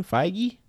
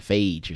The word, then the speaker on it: Feige: That's how you pronounce his name? Feige? – Yeah, what did you think it was? – I thought it was like Phage or something. Like beige.